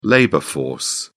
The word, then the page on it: labor force: British English